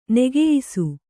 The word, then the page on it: ನೆಗೆಯಿಸು: Kannada